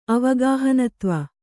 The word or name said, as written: ಅವಗಾಹನತ್ವ